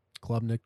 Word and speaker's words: Klubnik: And then uh Cade Klubnik, that's how you pronounce it? – Klubnik.